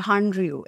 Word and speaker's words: Hallyu: The second point that I would dissect for everyone is, so there's actually this terminology that's called Hallyu. It's spelled as Hallyu, which is basically the explosion of Korean culture.